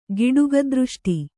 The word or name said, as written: ಗಿಡುಗದೃಷ್ಟಿ